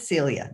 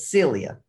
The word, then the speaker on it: Celia: Celia, not Cecilia, but Celia.